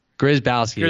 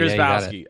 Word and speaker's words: Grzybowski: Grzybowski. Grzybowski.